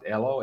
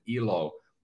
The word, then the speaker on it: Elo: So Elo, it means if we pronounce it Elo, it means life in Finnish. And if you pronounce it Ilo, as some people might, it means happiness.